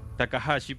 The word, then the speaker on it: Takahashi: Su nombre es Kenshi Takashi, Takahashi, perdón.